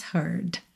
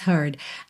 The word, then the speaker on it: heard: Três palavras aí iniciadas com H, heart has heard. Atenção, olha que interessante, heart, H-E-A-R-T, heard, H-E-A-R-D, né? Só muda a última letra na escrita, mas na pronúncia a gente tem bastante diferença, né?